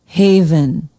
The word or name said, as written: haven